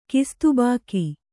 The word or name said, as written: ಕಿಸ್ತುಬಾಕಿ